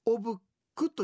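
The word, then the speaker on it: お仏供: 仏様に備えると書いておぶくとい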